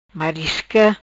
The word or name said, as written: Marisca